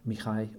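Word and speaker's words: Mihai: En toen werd hij opgevolgd door een zekere Michael, Michai, geloof ik, op zijn Roemeens uitgesproken.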